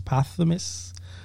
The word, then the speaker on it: posthumous: Pop Smokes, how do you say it? Posthumous? – Posthumous. – Yeah, his debut movie role.